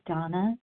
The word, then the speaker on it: Dana: My name is Donna B. It's spelled D-A-N-A, and I'm in Denver, Colorado.